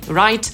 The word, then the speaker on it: write: To zależy, jak na to spojrzeć, bo jeżeli słowo zaczyna się w pisowni na WR, no to wtedy W nie wymawiamy. Tak jest na przykład ze słowem pisać, czyli write, albo niewłaściwy, czyli wrong.